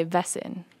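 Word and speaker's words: Väsen: And this is a Swedish fiddle tune, I guess you would call it a fiddle tune, written by Vesen band. It's spelled V-A-S-E-N.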